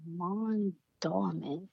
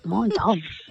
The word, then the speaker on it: Mondawmin: I'm like, Mon Dorman. – Mondawmin.